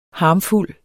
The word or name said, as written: harmfuld